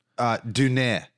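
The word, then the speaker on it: Döner: But I was just going to say that we got a thing called a donner, which. – We did. A donner, a donor. We don't know. – The guy was pronouncing it Duner. He was a German guy.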